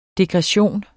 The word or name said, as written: degression